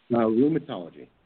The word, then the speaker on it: rheumatology: And I was just thinking about your whole, you know, your scientific field of ruminology. Is that how you pronounce it? – Rheumatology.